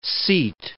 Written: seat

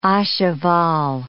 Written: a cheval

